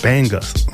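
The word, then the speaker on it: Bangas: That was coming from, what is it, Tank and the Bangles. Bangus. The Bangus, yeah. Pronounce it correctly. Pronounce it correctly. Tank and the Bangus.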